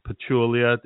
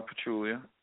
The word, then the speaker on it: Pachulia: But the three guys that are playing, Pachulia, am I pronouncing that right? – Yep. Zaza Pachulia.